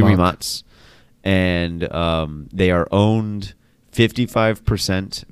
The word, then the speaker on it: Rimac: a merger between Rimac, or as we were pronouncing it last week, Rimac. – Yeah. – But they're Croatian, so it's actually Rimac.